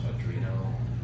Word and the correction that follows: Arduino: Arduino, whatever that's called.